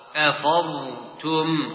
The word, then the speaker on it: أفضتم: أفضتم، ولا تقتل، لا تقول أفض، أفضتم.